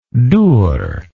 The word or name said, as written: dur